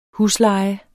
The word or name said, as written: Huslejen